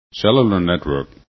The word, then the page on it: cellular: english